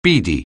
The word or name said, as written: beady